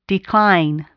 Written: decline

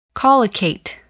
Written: collocate

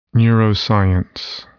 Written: neuroscience

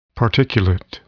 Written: particulate

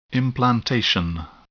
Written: implantation

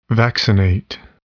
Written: vaccinate

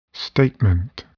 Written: statement

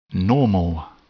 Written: normal

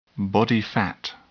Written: body fat

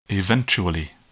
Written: eventually

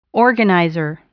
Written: organizer

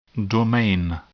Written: domain

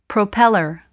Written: propeller

